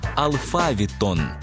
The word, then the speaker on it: алфавіт: Наголос у слові «алфавіт» на другому складі основний у нашій мові, бо збігається із грецьким «алфавітон», від якого походить українська лексема.